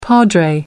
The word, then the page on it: padre: American English